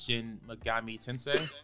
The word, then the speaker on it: Shin Megami Tensei: Launched around the same time as Shin Megami Tensei.